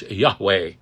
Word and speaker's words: Yahweh: Now, YHWH, you've seen, is also pronounced Yahweh.